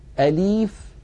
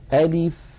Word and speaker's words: ألف: إذن كل هذا لا يصح أن ننطقها نطقا معتدلا. زمن الفتحة في أ مساو لزمن الكسرة في لي مساو لزمن البقية الحركات، ألف ألف هكذا من غير تكلف.